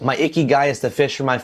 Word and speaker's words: ikigai: That word is spelled I-K-I-G-A-I, ikigai.